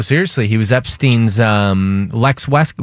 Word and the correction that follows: Wexner: What? How do you say it? – Lex West. – Lex Wexner.